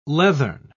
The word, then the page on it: leathern: British English